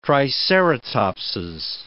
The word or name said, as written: triceratopses